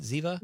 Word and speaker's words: Ziva: Ziva? – You are saying that right.